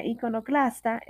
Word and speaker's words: iconoclasta: Esta palabra iconoclasta es una palabra llana, no se acentúa en la penúltima sílaba debido a que termina en vocal.